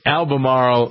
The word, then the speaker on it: Albemarle: Edith in Albemarle, North Carolina.